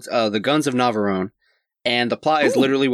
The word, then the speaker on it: Navarone: So the island they're on is called Navarone Island, if assuming I'm pronouncing that right. – Mm-hmm. Navarone.